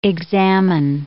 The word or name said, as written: examine